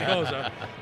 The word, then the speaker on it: Gose: Yeah, so, you know, it was my public service announcement to help people understand how to pronounce it, that by phonetically spelling, I thought as long as I'm doing Goza, is how I actually spelled out G-O-E-S-U-H. For people to know how to pronounce Goza.